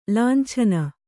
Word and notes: ಲುಂಚನ: kannada; luncana

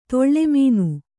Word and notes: ತಾಲಮಾನ: tāla māna

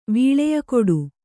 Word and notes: ವೀಳೆಯಕೊಡು: vīḷeya koḍu

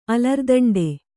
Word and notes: ಅಲರ್ದಂಡೆ: Kannada

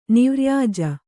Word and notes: ನಿವ್ರ್ಯಾಜ: nirvyāja

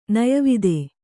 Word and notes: ನಯವಿದೆ: nayavide

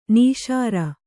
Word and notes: ನಿಸ್ಸಾರ: kannada

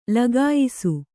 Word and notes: ಲಗಾಯಿಸು: lagāyisu

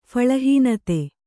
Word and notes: ಫಲಹೀನತೆ: phalahīnate

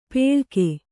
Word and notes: ಪೆಳಕೆ: peḷake; Kannada

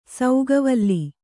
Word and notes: ಸೌಗವಲ್ಲಿ: sauga vlli

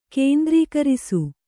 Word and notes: ಕೇಂದ್ರೀಕರಿಸು: kēndrīkarisu

